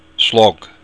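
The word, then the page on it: slog: Irish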